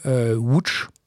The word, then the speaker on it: Łódź: qui s'appelle Łódź, mais dont le nom s'écrit L-O-D-Z.